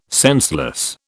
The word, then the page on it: senseless: British English